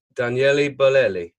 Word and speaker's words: Daniele Bolelli: I'm here with Maestro Daniele Bolelli.